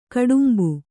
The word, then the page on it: ಗೋಡುಂಬೆ: gōḍumbe